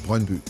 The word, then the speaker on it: Brøndby: Although I want to get the pronunciation right, Peter, it's Brøndby, right? – Brøndby.